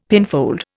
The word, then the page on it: pinfold: Indian